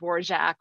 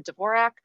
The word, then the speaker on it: Dvorak: I find it interesting that um, people will pop up on Twitter like crusade to have her name pronounced Vorjak, which, you know, perhaps I should as well. But I've just, you know, I've I've been a fan of hers for 25 years. I've always called her Dvorak. Robert Osborne pronounced it Dvorak. So that that's just always, you know, what what I've rolled with. I don't think anyway is incorrect since it wasn't her name anyway.